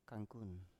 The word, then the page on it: kankun: Biak